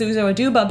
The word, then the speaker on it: Uzo Aduba: Like a few weeks ago, we talked about Uzo Aduba on the podcast. Mm-hmm. And I know her name is Uzo Aduba,